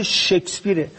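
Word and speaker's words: Shakespeare: بعد تا بالاخره یکی از هنرمندا گفت آقا به خدا تلفظ این واژه شکسپیره. اول از همه شما به عنوان وزیر فرهنگ این مملکت که ما متاسفیم شما وزیر ما هستید، اول از همه یاد بگیرید که نام این آدم رو درست تلفظ بکنید.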